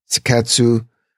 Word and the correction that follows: Seiketsu: Seiketsu. – And Shitsuki. – Shitsuke.